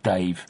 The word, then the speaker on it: Dav: Fucking millions of people are buying Dogman by Dave Pilkey or Dav Pilkey. Apparently it is pronounced Dave.